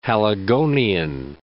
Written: Haligonian

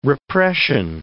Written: repression

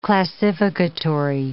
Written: classificatory

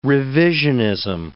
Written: revisionism

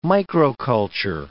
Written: microculture

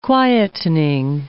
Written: quietening

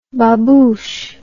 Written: babouche

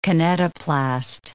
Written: kinetoplast

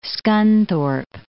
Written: Scunthorpe

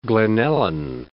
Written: Glen Ellyn

